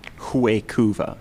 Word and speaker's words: Huecuva: And my thing to say is, the internet says that it is in fact pronounced from the Latin pronunciation, or like Latin American pronunciation, Huecuva. So Huecuva.